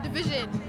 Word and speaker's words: DVSN: I don't know how to say his name. Division.